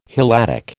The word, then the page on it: Helladic: American English